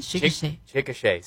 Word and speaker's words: Chickasha: Chickasha, sí.